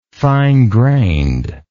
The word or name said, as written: fine grained